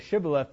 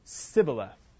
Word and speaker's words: Shibboleth: So they didn't say Shibboleth, they said Sibboleth. Right? They couldn't pronounce the sh sound.